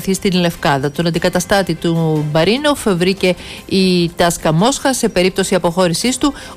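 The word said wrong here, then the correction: Τσεσεκά (ЦСКА): Συγγνώμη, η Τσεσεκά Μόσχα βέβαια.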